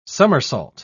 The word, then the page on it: summersault: British English